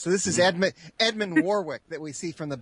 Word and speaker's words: Warwick: Warwick.